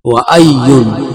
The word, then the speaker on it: وأي: وأي وأي وأي انطق به هكذا.